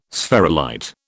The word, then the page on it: spherulite: British English